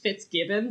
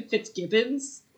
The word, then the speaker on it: Fitzgibbon: The second one is that in our previous episode, the Laura Secord episode, I mispronounced Fitzgibbon the whole time. I kept calling him Fitzgibbons. There's no S at the end.